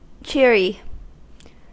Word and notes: cheery: American English

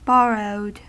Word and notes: borrowed: American English

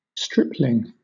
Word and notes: stripling: British English

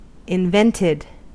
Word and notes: invented: American English

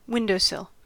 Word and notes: windowsill: American English

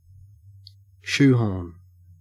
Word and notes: shoehorn: Australian English

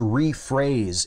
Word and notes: rephrase: American English

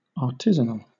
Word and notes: artisanal: British English

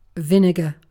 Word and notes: vinegar: British English

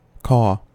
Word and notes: caw: American English

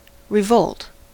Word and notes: revolt: American English

